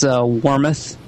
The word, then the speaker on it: Wormuth: It's Wormuth. – Wormuth. There you go. Okay. – There you go. – So it's less on the U.